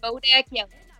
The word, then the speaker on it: Bodak Yellow: Bodak, is that how you say it? – Bodak Yellow. Yep.